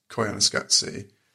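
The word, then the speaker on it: Koyaanisqatsi: I'm just really impressed with your pronunciation of it, because I didn't know that's how it was said. – Well, it's in the soundtrack, they go, Koyana Skatsi.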